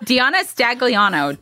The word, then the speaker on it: DeAnna Stagliano: DeAnna Stagliano.